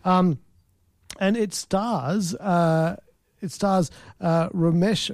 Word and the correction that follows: Romesh: Ra- sorry, I got to pronounce his name properly. Um, it stars Romesh Ranganathan, and he's a comedian.